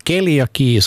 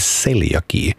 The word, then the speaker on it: celiaki: Idag ska det handla om celiaki som inte ska uttalas keliaki som jag har gjort utan det är celiaki.